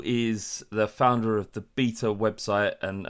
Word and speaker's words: B3TA: I'm doing very well, and thank you for correctly pronouncing Beta and not doing Beta or B3TA or one of the other many wrong versions of it, and doing my surname right and not doing Manuel.